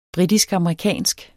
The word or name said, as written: britisk-amerikansk